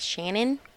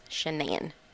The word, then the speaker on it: Shanann: Her parents pronounced her name as Shannon, mm-hmm. but she pronounced it as Shanann. It's okay. Spelled S-H-A-N-A-N-N.